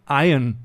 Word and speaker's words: Iron: Falls ihr euch jetzt wundert, was dieses Iron Maze ist, viele von euch werden wahrscheinlich denken, hey, Alter, das heißt doch Iron Maze, ist doch I-R-O-N. Nein, Iron Maze. Hier blende ich es euch nochmal ein. Iron, so spricht man das aus. A-I-U-H-N.